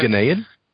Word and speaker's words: Ghanaian: Ghanaian.